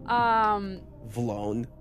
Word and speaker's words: Vlone: Vlone. Do you know what Vlone is? – What? – Vlone? – You mean V-Lone? – You spell it? – excuse me, it's a V-Lone? – Yeah.